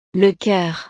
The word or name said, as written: le coeur